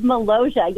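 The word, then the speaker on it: Molossia: Tell us about the Republic of Malaysia. Am I pronouncing it right? – You are correct. The Republic of Malaysia.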